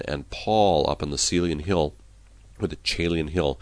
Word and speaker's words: Caelian: It was called the Monastery of St. Andrew on the Caelian Hill. Sometimes that's pronounced Caelian Hill.